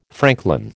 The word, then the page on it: franklin: American English